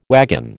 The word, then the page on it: wagon: American English